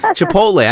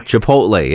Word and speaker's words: Chipotle: It's Chipotle. I can never say it right. – Chipotle. I think I'm saying it right. Am I saying it right, Dowden? – Yes, you are. – Chipotle, yeah.